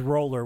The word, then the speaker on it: Roller: Like roller skate, roller coaster, like R-O-L-L-E-R. And they're like, oh, like it sounds.